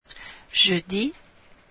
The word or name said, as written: jeudi